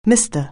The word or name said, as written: Mister